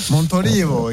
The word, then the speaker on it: Montolivo: Hein, ça va, je l'ai bien prononcé là? – Montolivo, excellent. – Très bon, t'es très bon.